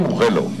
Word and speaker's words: Mugello: Mugello, ha dicho. – Mugello. – Entonces que se muge yo. – Vamos a Mugello. – No empecemos ya, va. – En italiano se dice Mugello, ya está.